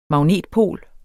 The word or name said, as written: magnetpol